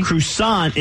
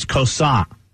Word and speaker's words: croissant: Croissant is not pronounced croissant. – How would you... – It's croissant.